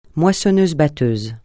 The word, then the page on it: moissonneuse-batteuse: francais